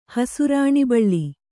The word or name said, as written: ಹಸುರಾಣಿಬಳ್ಳಿ